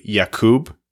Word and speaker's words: Jakub: It's actually, if you want to pronounce it properly, Jakub, but he says he's okay or even prefers just Jacob or Jake.